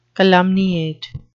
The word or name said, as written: calumniate